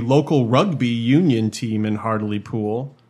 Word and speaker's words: Hartlepool: H-A-R-T-L-E-P-O-O-L. – Hartlepool, but it's pronounced Hartlepool.